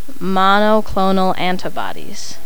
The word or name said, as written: monoclonal antibodies